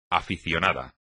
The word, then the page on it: aficionado: espagnol